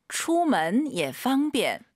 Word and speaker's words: 出门也方便: 出门也方便. – 자, 어때요? 너무 편하게 발음하시죠? 出门 예. 예가 올라가면 안 돼요. 예 올라간 순간 틀린 거죠. 뒤에가 일성이니까 반삼성이에요.